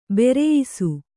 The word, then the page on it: ಭರಾಯಿಸು: bharāyisu